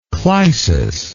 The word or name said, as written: clysis